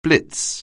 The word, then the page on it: blitz: anglais